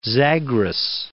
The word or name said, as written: Zagros Mountains